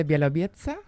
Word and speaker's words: Białowieża: En Polonia, en el Parque Nacional de Białowieża, que es reserva de la biosfera. Dilo otra vez, que me ha gustado mucho cómo te lo has pronunciado. – Parque Nacional de Białowieża.